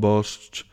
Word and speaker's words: Bosch: ¿Cómo se pronuncia Bosch? Como el anuncio. Con Bosch. Tu cocina es la mejor.